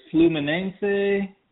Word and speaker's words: Fluminense: Yes, it is Corinthians versus Fluminense, and I did two plays on that game.